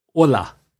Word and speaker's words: hola: For example, when you were saying hola, and hola, you see it written down, is spelled with an H. – Yes. – H-O-L-A. And in Spanish, we don't pronounce the H.